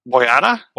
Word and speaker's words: Boyata: Boyata? – Boyata? I don't know. – B-O-Y-A-T. Excuse me, BOYATA.